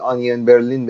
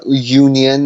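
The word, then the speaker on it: Union Berlin: توی زبون آلمانی یونین مثل پیاز تو زبون زبون انگلیسی تلفظ میشه یعنی آنین برلین بهش میگن به آلمانی یعنی یونین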